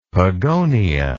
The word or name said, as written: pogonia